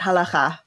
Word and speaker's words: halakha: There is Jewish law called the halakha. Am I saying that correctly? – You got to get that guttural ch in, but it's basically correct, halakha.